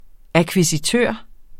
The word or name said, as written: akkvisitør